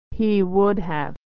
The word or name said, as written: he would have